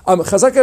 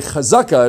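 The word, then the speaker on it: chazaka (חזקה): Okay, and in a chazaka, and again, it's pronounced that way in the Ashkenazi fashion, which is, you know, comes through Yiddish. Of course, in modern Hebrew, or in actually all Hebrew, it should be pronounced chazaka, accent on the final syllable.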